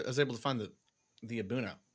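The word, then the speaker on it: A'bunadh: Was I supposed to just get the Abuna, which is spelled, by the way, Abunad with an H at the end? – Correct. The D is silent. It's pronounced Abuna.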